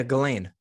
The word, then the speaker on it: Ghislaine: Well, her first name is spelled differently than it's pronounced. – Yeah, Ghislaine. – Yeah. It doesn't look like Ghislaine. – No. – It looks like Ulysse. – Yeah, there's a couple S's in there that don't belong.